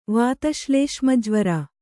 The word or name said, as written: ವಾತಶ್ಲೇಷ್ಮಜ್ವರ